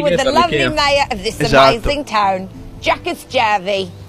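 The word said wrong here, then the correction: Jukkasjärvi: Did I pronounce that right? – No. – E gli abba dove li mettete? – Siamo a Jukas Jervi.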